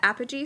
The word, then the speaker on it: Apogee: So I follow you on Instagram and everything, and I saw that you're doing I don't know how to pronounce it, the Apogee School. – That's it. You pronounce it perfectly.